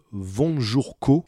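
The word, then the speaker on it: Von Jour Caux: Donc c'est un immeuble qui a été conçu par un architecte légendaire au Japon qui s'appelle Vonjurko. Et c'est un pseudo, ça s'écrit un peu à l'européenne, c'est V-O-N, jour comme un jour, et Ko, C-A-U-X, donc c'est un pseudo.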